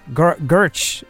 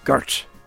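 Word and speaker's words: Gwrych: It's Gurch is how you pronounce the castle. – Gurch.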